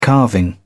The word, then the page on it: carving: British English